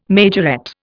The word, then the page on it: majorette: Indian